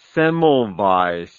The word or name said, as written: Semmelweis